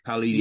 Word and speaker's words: Paulinho: Paulinho.